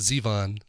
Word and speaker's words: Zevon: That's Billy Bob Thornton and a cover of The Wind that he recorded for the Enjoy Every Sandwich, the songs of Warren Zevon. Well, I almost did it, Warren Zevon. See? Gotta catch me on that. Make sure I don't go off the wagon in my pronunciation of Zevon.